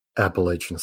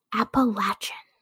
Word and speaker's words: Appalachian: Appalachian State. – Um, that's not how we pronounce that word. And yes, that is correct.